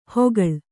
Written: ಹೊಗಳ್

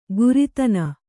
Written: ಕುರಿತನ

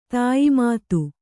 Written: ತಾಯಿಮಾತು

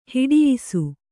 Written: ಹೊಡಯ್ಸು

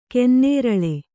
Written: ಗನ್ನೇರಳೆ

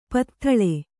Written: ಪದತಳ

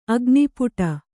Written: ಅಗ್ನಿಪುಟ

